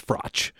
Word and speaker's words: Froch: Carl Froch or Froch. I already don't remember how to pronounce it. – Well, you said Froch earlier. – Okay, then that's what it is. Because I know it was. I thought it was maybe Froch, but it's Froch.